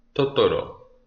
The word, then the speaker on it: Totoro: Didoro, Didaro, egal, Hauptsache Barcelona. – Barcelona. Rainer Kumpel, vielen Dank. So, jetzt hör zu, hör zu, hör zu. – Totoro.